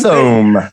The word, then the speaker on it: Thome: That's what I put, but I no thought it was Tomey. Oh, hey. I put Jim Tomey. – Let's go. – Jim Tomey. – I did not pronounce Thome.